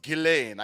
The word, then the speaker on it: Ghislaine: One of it, Ghislaine's, and also I know the pronunciation of her name is like Ghislaine.